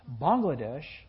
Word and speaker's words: Bangladesh: Bangladesh is the right pronunciation. They are not a nation of frogs.